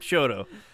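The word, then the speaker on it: Chouteau: It's spelt like Chateau. Nope, Shoto. – Oh, man. – I hate that. It's Chateau. It's totally Chateau.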